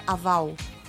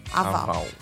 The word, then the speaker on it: Haval: Haval. – Haval.